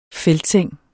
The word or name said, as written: feltseng